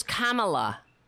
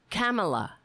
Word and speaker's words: Kamala: What does he call it? Kamala? Kamala? – Oh, yeah, that was great. – Her name is Kamala. Kamala.